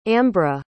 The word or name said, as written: ambra